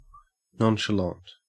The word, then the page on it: nonchalant: Australian English